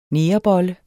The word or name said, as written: negerbolle